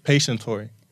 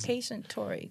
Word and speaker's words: Patientory: Patientory. – How do you spell that? Patient. – Like patient. – Like a health, hospital like patient. Yeah. Okay. – And then ORY. So her focus is Patientory.